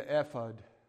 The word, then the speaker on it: ephod: On top of the blue robe, you'll see the ephod, if I'm saying that right, it's E-P-H-O-D, ephod, ephod, ephod.